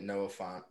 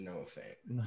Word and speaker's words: Noah Fant: I don't know why we're saying it Noah Font, but his name is Noah Fant.